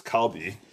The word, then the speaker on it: Kalbi: Okay, so we're going to talk about barbecue, my adventures right now of doing this Calbee. For those of you who are not aware of what Calbee is, it's spelled K-A-L-B-I.